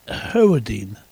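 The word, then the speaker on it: Hewerdine: How do you pronounce him? Howardine. W-E-R, because it's dark here, D-I-N-E. H-E-W-E-R-D-I-N-E.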